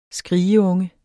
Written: skrigeunge

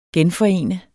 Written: genforene

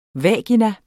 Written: vagina

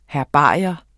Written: herbarium

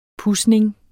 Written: pudsning

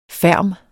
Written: ferm

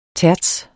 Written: terts

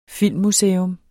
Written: filmmuseum

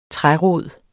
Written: trærod

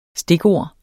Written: STIKORDETS

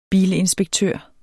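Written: bilinspektør

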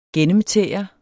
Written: gennemtære

